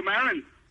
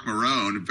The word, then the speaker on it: Maron: Marco Maron. – Marco Maron. Yeah. You hear all those guys mispronouncing Maron's name? He was like, Mark Marone, very popular podcast, WTF.